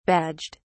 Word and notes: badged: British English

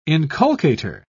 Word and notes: inculcator: British English

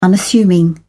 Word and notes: unassuming: British English

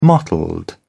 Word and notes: mottled: British English

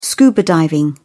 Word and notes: scuba diving: British English

